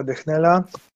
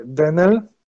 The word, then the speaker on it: Dechnel: Dwóch komentujących e, zwróciło nam uwagę, że nazwisko znanego literata Jacka Dechnela poprawnie wymawia się Denel. E, nie byliśmy tego świadomi, dlatego nazwisko Jacka Dechnela wymawialiśmy Dechnel. Chcielibyśmy przeprosić wszystkich słuchaczy oraz samego Jacka Dechnela i zapewnić go, że nie będziemy już e, omyłkowo przekręcać jego nazwiska.